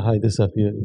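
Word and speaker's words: Hayedeh Safiyari: Am I saying it correctly? – Yeah, Haide Safiani.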